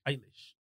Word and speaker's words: Eilish: Eilish.